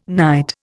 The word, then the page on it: knight: Indian